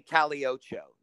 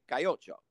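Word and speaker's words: Calle Ocho: they don't say Caliocho. They say Cayocho, and everybody knows how to pronounce it.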